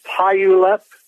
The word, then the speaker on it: Puyallup: It's called Puyallup, P-U-Y-A-L-L-U. – Puyallup, Puyallup.